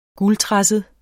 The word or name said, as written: guldtresset